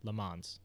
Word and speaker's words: Le Mans: It's, there's no T. Le Mans. – Yeah, it's it's spelled L-A as a word, and then M-A-N-S.